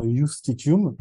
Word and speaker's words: justitium: il y avait ce qu'on appelait ça s'écrit avec un J, justitium, mais ça se prononce certainement justitium, c'est la suspension de la loi.